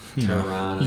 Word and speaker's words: Toronto: Toronto. – You got to cut it. – Can't pronounce the T. – Can't pronounce that second T. – It's mountain, not mountain. – Yeah. – Toronto. Toronto.